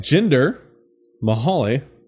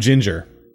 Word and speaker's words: Jinder Mahal: Dude, I can never get his name right. – I gotta say, I'm proud of you. – Yeah. I was calling him Ginger. Not Ginger. Yes.